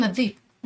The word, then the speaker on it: الذئب: قريش لا ينطقون الهمزه فمثلا بدل ما يعني ينطقون كلمه الذئب فكانوا يقولون الذيب